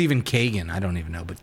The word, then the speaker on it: Keoghan: Barry Keoghan, or Kogan, The Banshees of Inisherin. – How do you say that? Is it Keoghan? – I like that. Keoghan.